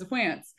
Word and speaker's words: Wantz: And then, you know, I looked at his baseball reference page and it literally says Wantz rhymes with pants.